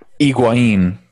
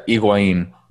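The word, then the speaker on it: Higuaín: How do you pronounce it? – Higuain. – Higuain, okay. – Yes, Gonzalo, close. Yeah, Higuain. – I love Higuain, okay. – Yeah, the H is silent.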